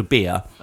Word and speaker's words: bier: It's actually called a bier. – Oh, right. – But it's spelt B-I-E-R.